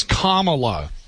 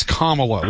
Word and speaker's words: Kamala: It's Kamala. It's Kamala. Like comma, Kamala.